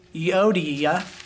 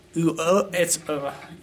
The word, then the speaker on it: Euodia: Now, how many girls do you know named Euodia? I think that's how it's pronounced. I always say Euodia.